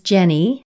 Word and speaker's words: Genny: my name is Jenny with a G.